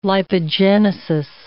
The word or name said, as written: lipogenesis